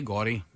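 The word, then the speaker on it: DelGaudio: Yeah, so we're going to have, Derek Delgadio. – Yes, thank you. Derek Delgadio. Or Gaudio, is that how you say it? – Gaudio, Gaudio. – Gaudio, yeah.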